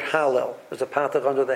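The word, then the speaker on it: halel (הלל): So, but there's a negation on the lamed, so we pronounce it halel. So really, it's hal, and then lel.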